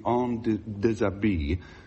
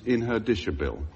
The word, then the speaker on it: en déshabille: In those days, they were in naughty books, if a girl was somewhat undressed, they referred to it as being en déshabille, which we always pronounce as in her dishabille.